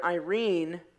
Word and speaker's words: Eirene: That word there, Irene, it's spelled E-I-R-E-N-E.